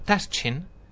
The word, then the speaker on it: Taschen: Pero antes de nada, recomendaros un libro maravilloso de la editorial Taschen, o Taschen. Depende, si vosotros... – Depende de si eres tonto o no eres tonto. – Si pronunciáis Caché de Michael Haneke, pues es Taschen. Y si no, es Taschen.